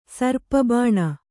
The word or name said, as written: ಸರ್ಪಬಾಣ